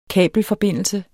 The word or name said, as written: kabelforbindelse